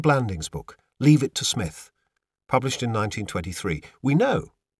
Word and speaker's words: Psmith: It's Thorpe and Briscoe's Coal Merchants, because it's where Smith, you may know that it's spelled P-S-M-I-T-H, the P is silent, that Smith sees a beautiful girl sheltering from a downpour underneath the awning, underneath the canopy of the coal merchants.